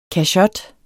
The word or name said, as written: kachot